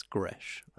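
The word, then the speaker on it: Gretsch: Is that how you pronounce it? I've seen the words. I just thought it meant Gresh. I think it's pronounced Gresh. – It's Gresh.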